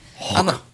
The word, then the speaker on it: Hawke: They were very proud of this ship, but it was involved in a collision with a ship called the HMS Hawk, H-A-W-K-E. If it's pronounced a slightly different way, I'm sorry. – Hawk. – I'm American. Okay. – I'm sure the E is silent.